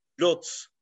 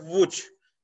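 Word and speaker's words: Łódź: Yani en başından şimdi Türk'ün çoğu Lot diye geliyor adam. Burası Lot diyor ama Vuç. Yani Hadi bunu gel açıkla. Lots diye yazılıyor. Adam Buç diye okuyor.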